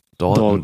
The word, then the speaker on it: Dalton: Ich habe das immer so ausgesprochen wie die Lucky Luke-Brüder. Dalton.